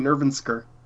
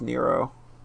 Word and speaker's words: NRVNSQR: Then there's a guy whose name is Nero, but it's spelled NRVNSQR. – That's how you spell it? I was saying Nervensker. – I think it's pronounced Nero. – Okay, he's not Nervensker Chaos. – No, it's just Nero.